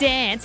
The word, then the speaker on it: DNCE: D-N-C-E. – Oh, I said dance. How else do you say that? – No, it's pronounced D-N-C-E. – Oh, mate, that is so...